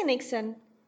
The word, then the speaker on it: Niksen: Is it Niksen or how do you say that? – Yeah, you'll say Niksen.